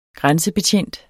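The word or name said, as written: grænsebetjent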